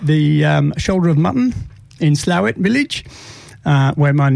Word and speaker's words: Slaithwaite: Well, it's pronounced Slawit, but if I spelt it out for you, S-L-A-I-T-H-W-A-I-T-E, you would say Slathwaite. – Slathwaite. – But the local pronunciation is Slawit.